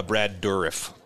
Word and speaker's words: Dourif: Bergdorf guy. What's his name? – Brad Dourif. – Damn, Dourif. – You done butchered that one.